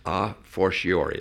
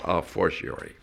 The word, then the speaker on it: a fortiori: A space F-O-R-T, fort. A space F-O-R-T I-O-R-I. That's a fortiori. It looks like a fortiori, but it's pronounced a fortiori.